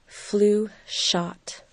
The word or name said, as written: flu shot